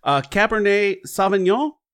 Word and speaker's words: Cabernet Sauvignon: Cabernet Sauvignon? – Sounds good to me.